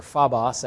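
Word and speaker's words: phobos (φόβος): This is a Greek word that is phobos or phobos, actually, the short O sound, but either way you say it.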